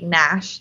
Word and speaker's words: Nash: And my personal Instagram is my full name at Cassidy Nash Davis, N-A-S-H Davis. – And mine's my full name at Mallory Fusella, M-A-L-L-O-R-Y.